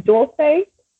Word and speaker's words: Dulce: Yeah, well, let's talk about, and let me make sure that I'm pronouncing this right, Dulce? – Yes.